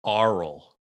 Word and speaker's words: aural: Yep, aural. Not the oral, sounded, but aural.